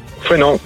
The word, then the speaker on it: Fouesnant: Ça se prononce Fouesnant ou Fouesnant. – Fouesnant, je l'ai bien dit.